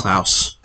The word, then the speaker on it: Klaus: It's Klaus.